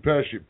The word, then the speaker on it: Pechie: P-E-C-H-I-E, is it? – Yeah, and I asked his publicist, I'm like, so how does he say it like Pesci? She's like, yep, just like Joe Pesci.